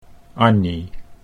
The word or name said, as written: Annie